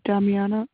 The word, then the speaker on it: Damiana: My name is Damiana Aguas-Biba. – Can you spell that, please? – D-A-M-I-A-N-A. My last name is A-Y-G-U-A-S-B-I-B-A.